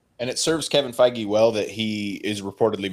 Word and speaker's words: Feige: Kevin Feige, Brian, I said it right. Kevin Feige has, thank you.